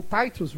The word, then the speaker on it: Titus: E olha que interessante, olha a visão do Benjamin Titus Roberts, ou Titus Roberts, né, que a pronúncia correta é essa.